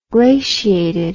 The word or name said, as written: glaciated